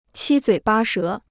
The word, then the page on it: 七嘴八舌: qī zuǐ bā shé